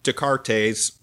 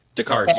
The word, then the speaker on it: Descartes: And she was at Descartes, or what was that? Yeah. The coffee place. Descartes, yeah. Sorry, I pronounced the ES at the end.